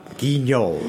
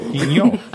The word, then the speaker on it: Guignol: Guignol. – Guignol.